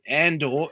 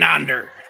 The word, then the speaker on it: Andor: It's Ender. Not Cassian Andor. It's Cassian Under.